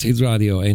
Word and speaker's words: Hit Radio 1: Ja, ik had Hit Radio 1 had ik op... nou, zeg ik dat goed? Ja, ja, ja.